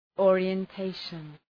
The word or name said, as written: orientation